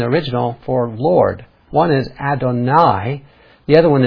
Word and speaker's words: Adonai: If you look at the words behind those English words, it's Lord Jehovah, or in the original, it's a word called Adonai, is the way you pronounce it. Adonai.